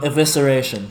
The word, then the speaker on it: evisceration: Evisceration.